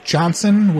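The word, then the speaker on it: Johnsonn: D-W-I-G-H-T. – And your last name. – The last name is Johnson with two N's. J-O-H-N-S-O-N-N.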